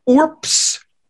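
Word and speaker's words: urbs: By the way, that word is pronounced orps. When BS is like that at the end, you pronounce it like it's as if it were spelled PS.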